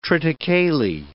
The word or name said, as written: triticale